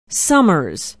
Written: somers

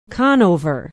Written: Conover

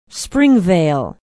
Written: springvale

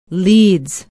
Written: Leeds

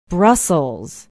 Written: brussels

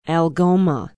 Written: algoma

